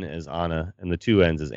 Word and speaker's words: Ana: And it is pronounced Ana, I confirmed.